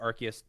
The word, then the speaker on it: Arceus: And so do we have confirmation that it is pronounced Arceus? – Yes, they pronounced it Arceus 3 times in the presentation. – Okay. – So it's been Arceus, Arceus, Arceus, Arceus. So it's like it is Arceus.